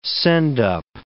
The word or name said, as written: send-up